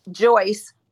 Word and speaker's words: Joyce: Joyce. – Say your name. – You can say Joy, J-O-Y, Joy. – I can say Joy? – Joyce, J-O-Y-C-E, but Joy is probably easier.